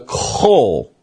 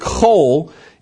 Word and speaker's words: Chol: Now, even the Jews, the Hebrews, the Israelites, they had a concept of a phoenix-like bird that they called the, I'm going to try to pronounce this, the Chol. C-H-O-L, all right?